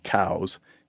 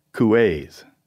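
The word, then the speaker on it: Coues: And he says in that same footnote, when they moved to southern England, the name was changed or bastardized, and they started pronouncing it cows. And in France, it was two syllables, couets.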